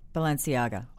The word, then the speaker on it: Balenciaga: Balenciaga? – Balenciaga. – Oh, I was close. – That's the guy's brand that sells the dirty jeans. – Oh, really? – And stuff. Yeah, Balenciaga.